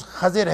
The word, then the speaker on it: خضر: جس کو عام طور پر خضر کہا جاتا ہے، اس کا درست تلفظ خضر ہے لیکن معروف خضر ہے۔